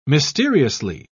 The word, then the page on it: mysteriously: British English